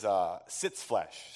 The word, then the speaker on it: Sitzfleisch: The way to pronounce it is Sitzfleisch, so pretty simple.